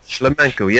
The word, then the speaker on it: Shlemenko: Shlemenko, yes.